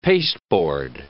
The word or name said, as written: pasteboard